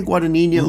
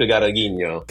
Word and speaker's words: Guadagnino: How do you pronounce that last name? I say Guadagnino. – Luca. Well, I say it quickly, so I can kind of elide my mispronunciation, but Luca Guadagnino.